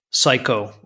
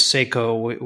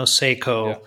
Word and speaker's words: Seiko: So Seiko would actually be Psycho over here. But yeah, growing up, it was always Seiko. We pronounced it sort of like S-A-Y-K-O, you know, Seiko.